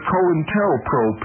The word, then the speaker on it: COINTELPRO: In 1990, Ward Churchill and Jim Vander Waal wrote the COINTELPRO papers, documents from the FBI's secret wars against dissent in the United States. The name, the term, COINTELPRO, pronounced co-in-tel-pro, is a contraction of counterintelligence program,